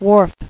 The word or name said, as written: Wharfs